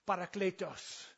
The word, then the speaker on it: parakletos: But I think that the correct pronunciation is parakletos. You know, that was more like, you know, Spanish than Greek, but you know, they're similar.